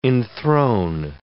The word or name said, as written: enthrone